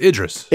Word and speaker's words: Idris Elba: It's Idris. – Idris. – Idris Elba. – Idris Elba. Gotcha. – All right. So, but Will Smith is a way bigger name than Idris Elba.